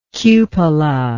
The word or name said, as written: cupolaed